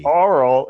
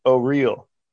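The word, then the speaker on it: Auril: So oral is not oral. Nope. It's O-real.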